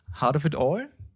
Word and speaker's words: Heart of It All: Hm, vergessen haben wir es nicht, aber wir sollten über den Rest vielleicht im Zuge unserer Hard of It All Thematik sprechen, da ja. Okay, Mr. Potter. – Heart of It All? All? Ähm, richtig ausgesprochen.